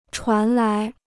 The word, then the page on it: 传来: chuán lái